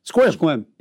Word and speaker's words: Sequim: There's a town up there, for example, that looks like Sequim, right? But it's pronounced Squim.